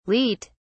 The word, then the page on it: leat: British English